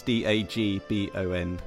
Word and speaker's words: Dagbon: I recommend, lastly, if everyone wants to check out your site, it's Boy from Dagbon, and that's Dagbon, in case I'm not saying that correctly.